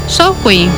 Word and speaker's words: Samhain: Samhain, que se pronuncia como Samhain.